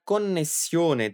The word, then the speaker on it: connessione: A palavra connessione tem duas duplas. São dois N's e dois S's. Então capricha na hora de pronunciá-la.